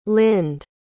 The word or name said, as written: lind